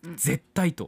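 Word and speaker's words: 舌苔: 絶対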